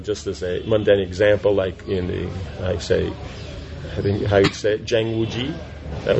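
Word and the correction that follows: Zhang Wuji (张无忌): Zhang Wuji, yeah, okay, it's a person's name.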